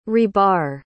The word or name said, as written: rebar